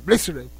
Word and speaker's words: Blitzkrieg: Blitzkrieg Bop de Ramones. No sé si está bien pronunciado. – Es en alemán. – Es Blitzkrieg. En alemán. Blitzkrieg.